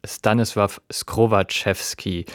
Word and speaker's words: Stanisław Skrowaczewski: Gespielt hat das Radiosymphonieorchester Saarbrücken und geleitet hat es Stanisław Skrowaczewski. Diesmal habe ich den Namen auch richtig ausgesprochen.